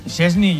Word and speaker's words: Szczęsny: Szczęsny, yo digo Szczęsny, porque es lo que leo. ¿Cómo es la pronunciación correcta? – ¿Cómo?